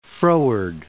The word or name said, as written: Froward, Cape